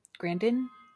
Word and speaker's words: Grandin: There's a great book that recently came out called Visual Thinking that is Temple Grandin. Mm-hmm. That's how you pronounce her name.